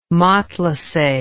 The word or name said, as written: matelasse